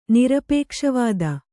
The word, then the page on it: ನಿರಪೇಕ್ಷವಾದ: Kannada